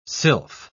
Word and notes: sylph: British English

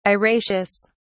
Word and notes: araceous: British English

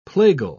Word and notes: plagal: British English

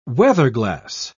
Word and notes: weatherglass: British English

